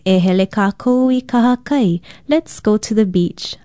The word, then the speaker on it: kahakai: Aloha, I'm Leilani Poliahu of Hawaii Public Radio, and our Hawaiian word for today is kahakai. Kahakai is spelled K-A-H-A-K-A-I, and it means beach, seashore, seacoast, seaside, or strand.